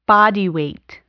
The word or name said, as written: body weight